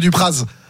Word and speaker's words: Dupraz: Droit de réponse, monsieur Dupraz. – Ouais. Donc vous n'êtes pas savoyard, vous, par contre, néo-savoyard, parce que normalement on prononce pas le Z, mais c'est pas grave.